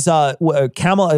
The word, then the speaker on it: Kamala: You know, Kamala Harris was, Kamala, am I saying it right? – Kamala. – Is it Kamala? – Kamala Harris. Kamala.